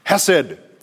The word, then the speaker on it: hesed: Hesed.